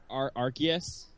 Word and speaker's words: Arceus: Like, it's Arceus is how it's pronounced.